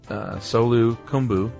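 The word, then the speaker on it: Solu Khumbu: You pronounce the region, Solu Kumbu, correctly, and he was very excited about that.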